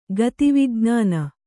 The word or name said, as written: ಗತಿವಿಜ್ಞಾನ